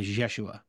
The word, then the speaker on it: Jeshua: And the pronunciation I heard was a J with a Y and a J mix, kind of a Jeshua.